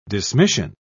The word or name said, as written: dismission